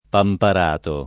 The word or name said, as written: Pamparato